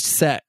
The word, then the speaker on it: CEX: No, the book is called It's All About CEX, where CEX is pronounced set.